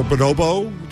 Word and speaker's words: Bonobo: This was a concert for Bonobo.